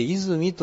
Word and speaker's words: 和泉: それからこの 68 ページの下から 2行目の真ん中に、泉山脈、和泉と書いて泉と読むんですけども、項目別地図帳の 12 ページに、旧国名がありましたね。